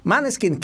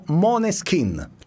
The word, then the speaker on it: Måneskin: Faccio sentire un piccolo estratto, insomma, delle due canzoni incriminate, cioè quella appunto dei Maneskin, che oggi, vedendo un'intervista, ho scoperto si pronuncia Måneskin.